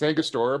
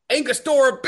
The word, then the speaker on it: Angostura: I can never pronounce Angostura. – No, well, and you still can't today. You still can't. It's Angostura bitters.